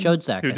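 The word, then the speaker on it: Schoedsack: Is that how you say his name? – Shodstack, Who was half of the team that did King Kong.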